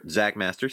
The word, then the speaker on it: Xakkmaster: You can find me on Critiker, Zach Master, spelled with an X.